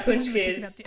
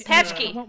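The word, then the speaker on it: pączki: Yeah, punchkis. – Pachki. – I want one. – Punchki. – Yes. Yeah, punchki. Yes. It's pronounced, it's spelled kind of pachki, but it's pronounced punchki.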